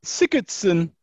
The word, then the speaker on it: Sigurdsson: Second midfield pick is Sigurdsson of Everton. – Sorry, who now? – Sigurdsson. Sigurdsson. – Is that how they say it in Reykjavik? – It is.